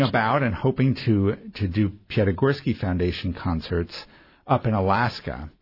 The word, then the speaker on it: Piatigorsky: I also understand that you're part of the Piatigorsky Foundation. Am I pronouncing that correctly? – Yes. Yeah. Okay. Gregor Piatigorsky was my grandfather.